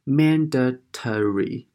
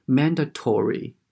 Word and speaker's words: mandatory: 那么它英式读法的话呢，还有一个就是。 mandatory 就是 t o 发成 t，tory。那么美式读法的话呢，它会读作是 mandatory，就这个 o 会读作是 o 这个音。